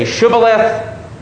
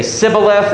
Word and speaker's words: Shibboleth: So that east of the river, they say Shibboleth. West of the river, they say Sibboleth. And apparently the Westerners are unable to say Shibboleth.